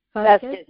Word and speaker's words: Vazquez: I was looking at Kelly Marie, and it's Vazquez, right? – Vazquez, yes, yes.